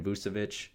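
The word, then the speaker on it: Vučević: So the Bulls get an all-star here in Vucevic. That's how you say it right there, Vucevic, not Vucevic. Vucevic, there we go.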